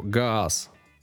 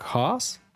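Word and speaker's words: Haas: И, как вы понимаете, во-первых, скорее всего, Гаас это надо произносить как Хаас, а во-вторых, скорее всего, вряд ли маленького немецкого мальчика в обычной католической семье назвали Федором Петровичем.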